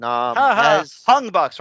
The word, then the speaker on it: Hung Bucks: Listen, Hung Bucks, rhymes with Young Bucks.